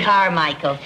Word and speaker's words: Carmichael: Carmichael. C-A-R-M-I-C-H-A-E-L. I always spell it for people because sometimes they spell it E-A-L, and we have the Scotch clan spelling A-E-L.